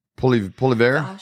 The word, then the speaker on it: Poilievre: Poilievre.